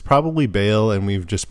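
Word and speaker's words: Baal: They say Bale in the game. – Yeah, so it's Bale.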